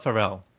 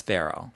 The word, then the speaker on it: Ferrell: But it's Ferrell. – Yeah. – Ferrell. – It's not Ferrell. – It's Ferrell.